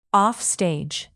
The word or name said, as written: offstage